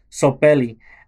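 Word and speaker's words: Tsopelli: Now, it's spelled with a T, T-S-O-P-E-L-L-I, Sopelli.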